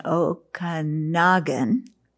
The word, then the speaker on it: Okanagan: So yesterday, I spoke to a class at the University of British Columbia in Okanagan, I pronounced it properly, and it was a graduate seminar, and they were talking about, you know, mindfulness.